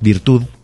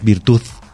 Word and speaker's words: virtud: La palabra es virtud, uh-huh. no es virtud. Uh-huh. Y tampoco por cambiarla por una T. Uh-huh. Tampoco es la virtud. Uh-huh. Entonces es una pequeña diferencia de pronunciación y la D es una letra muy diferente a la Z o uh-huh. muy diferente a la T. Uh-huh. Por lo tanto, se pronuncia, pero se pronuncia suave.